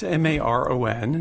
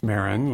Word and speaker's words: Maron: It's Maron, and it's pronounced Maron, like Baron. – Yes. – Everything is good except for moron.